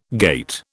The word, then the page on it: gait: British English